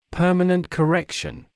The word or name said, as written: permanent correction